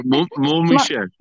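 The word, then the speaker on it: Mont-Saint-Michel: So I visited, Saint Mont-Michel. Not pronouncing that correctly. You think a Canadian could speak French, but right. Oh gosh. – Mont-Michel. – Mont-Michel. Yes. Thank you.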